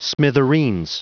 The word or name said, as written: smithereens